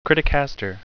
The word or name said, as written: criticaster